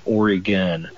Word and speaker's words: Oregon: But some people say, when people say Oregon, or one of my favorites, Oregon, I always just say, pronounce it O-R-Y-G-U-N, Oregon, Oregon, like that.